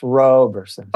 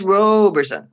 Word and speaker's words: Roberson: It's a long O, so it's Roberson. – Oh, it's Roberson. well, that's good.